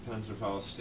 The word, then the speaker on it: Panzerfaust: Panzerfaust, yeah.